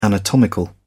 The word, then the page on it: anatomical: British English